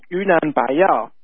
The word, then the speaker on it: Yunnan Baiyao: It's actually pronounced Yunnan Baiyao.